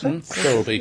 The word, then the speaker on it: Thirlby: It also says here, the film also stars Juno's Olivia Thirlby, is that pronounced? – Thirlby, yes.